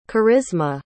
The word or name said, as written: karisma